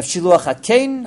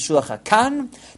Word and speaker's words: Shiluach HaKein: The Gemara there talks about the mitzvah of Shiluach HaKein, others pronounce it Shiluach HaKan, the mitzvah of sending away the mother bird before taking the chicks or the eggs out.